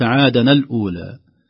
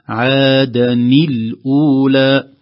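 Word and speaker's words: عادا الأولى: عادني الأولى، عادني الأولى، ربما بعض الناس لا يستطيع نطقها، وخاصة أنها تنوين بالفتح، فقد يقول بعضهم أنه أهلك عادنا الأولى، يرى تنوين بالفتح فيظن أن النون تنوين تفتح، لكن الصحيح أن نون التنوين المنطوقة غير المكتوبة عادا، هذه نون ساكنة وهي نون التنوين، إذا نطقناها أو صناها بما بعدها نكسرها على اعتبار التقاء الساكنين فيكسر الأول، فنقول عادني الأولى.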